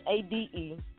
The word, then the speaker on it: Sade: My first name it does not sound how it's spelled. So it's Sade, last name Curry.